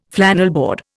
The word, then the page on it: flannelboard: Indian